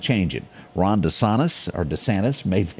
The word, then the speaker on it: DeSantis: Now, I have to take up for Ron DeSantis on this. Did I say that right? – Yep. – Okay, DeSantis.